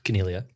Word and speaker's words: Coniglio: Coniglio. There's no G. It's a silent G.